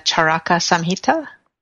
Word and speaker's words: Charaka Samhita: The awareness that the community plays a central role in Ayurvedic healing is illustrated by this quote, which actually you provided, Devi, am I saying it correctly, the Charaka Samhita? – That's correct.